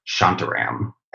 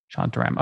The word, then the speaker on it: Shantaram: Shantaram. Shantaram. – Shantaram.